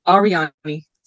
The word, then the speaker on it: Ariadne: Yeah, a lot of people don't know how to pronounce it. It's Ariadne. – Ariadne. With a D? – Yes, it's A-R-I-A-D-N-E. I guess the D is silent.